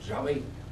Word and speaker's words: Yahvé: Este es el término que más o menos se acomoda a este vocablo que en hebreo se pronuncia Yahvé, poco más o menos.